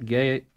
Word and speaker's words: Gaetz: You want to start with Matt Gaetz 5A? – Yeah, 5A. Pull that one up. And did we ever learn how to say his last name? – It's Gaetz. – Is it Gaetz? – Yeah.